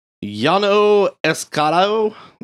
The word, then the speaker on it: Llano Estacado: Llano Estacado.